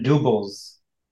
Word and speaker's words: dubbel: Belgian doubles. D-U-B-B-E-L. – Hardest word to pronounce for me, probably. I just can't not say double.